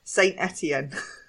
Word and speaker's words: Saint-Étienne: Also, can I just say, you said Saint-Étienne, and I'd just like the listeners to know that I pronounce it Saint-Étienne.